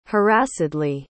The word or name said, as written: harassedly